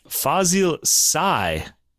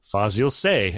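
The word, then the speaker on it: Fazıl Say: Fazil Say would be the Turkish way to say it. But the French say, Fazil Say, as I guess English people would too. So I guess this is one of those cases where you can choose your pronunciation. I'll pick Say because he's Turkish and I just want to stick with his the way he grew up hearing his name.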